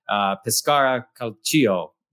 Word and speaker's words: Pescara Calcio: It's a town on the Adriatic, Pescara Calcio. Don, you can correct my pronunciation. – That was pretty good.